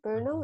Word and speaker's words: Brno: Brno mm-hmm. is how you say it.